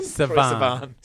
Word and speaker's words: Sivan: Sivan.